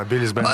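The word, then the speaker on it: Billy's Band: Я что, правильно говорю? – Да, Биллис Бенд э, здесь.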